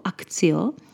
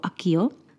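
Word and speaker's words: Accio: Já jsem si na různých Potter akcích všimla, že u nás v češtině se obecně tady to zaklínadlo čte často jako Akcio. Tak doufám, že ti z vás, kteří ho takhle čtou, tak zvládnou poslouchat moji výslovnost, protože já ho čtu jako Akio. Tohle je výslovnost, kterou já mám zažitou, protože se to tak vyslovuje v Británii a hlavně protože tak nějak by se pravděpodobně tohle zaklínadlo četlo v latině, což je jeho zdrojový jazyk.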